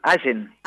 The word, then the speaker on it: Allen: Allen. – Allen, acento en la A.